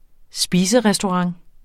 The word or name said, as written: spiserestaurant